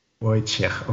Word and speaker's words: Wojciech: Wojciech. – Wojciech. – Wojciech. Okay, also es wird nicht mit quasi K am Ende ausgesprochen, sondern mit einem CH. – Ja, CH Wojciech. Okay.